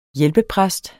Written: hjælpepræst

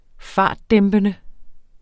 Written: fartdæmpende

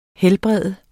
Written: helbrede

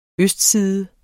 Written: østside

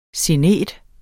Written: SIGNETER

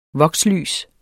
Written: vokslys

